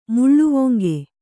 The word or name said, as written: ಮುಳ್ಳುವೊಂಗೆ